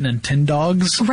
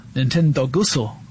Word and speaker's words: Nintendogs: That was when Nintendo released Nintendogs. – Right, yeah. Was that originally for the DS? – It was for the DS, yeah. Nintendogusso. I butchered that one.